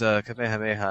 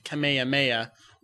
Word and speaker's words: Kamehameha: Whereas Kamehameha, I'm not sure the actual meaning of the name, but it's Hawaiian, and so it has its own pronunciation. – Right, and it's interesting to note that when Funimation originally began dubbing it, they went with Kamehameha, which is pretty close to that Hawaiian king pronunciation, but incorrect.